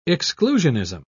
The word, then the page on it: exclusionism: British English